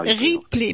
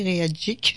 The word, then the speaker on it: Ripley: En fait, c'est Ripley comme un Ripley. Ben, je sais pas pourquoi j'ai dit réagique, je sais pas. Ripley.